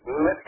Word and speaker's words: Metz: Metz, M-E-T-Z, Metz.